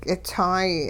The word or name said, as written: k'itsaay